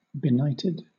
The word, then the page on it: benighted: British English